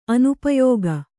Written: ಅನುಪಯೋಗ

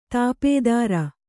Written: ದಪ್ತರು

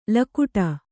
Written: ಲಗುಡ

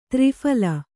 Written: ತ್ರಿಫಲ

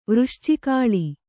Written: ವೃಶ್ಚಿಕಾಳಿ

